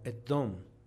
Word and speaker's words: Edom: Y aquí esta palabra se pronuncia como Edom, que traduce rojo.